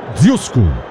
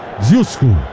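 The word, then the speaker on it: Ožujsko: Eu tô tentando ver aqui como é que eu falo. É Dzyusko. Se escreve DZ com aquele circunflexo ao contrário, UJSKO. Dzyusko. Rapaz, e não é que é boa a cerveja dos caras?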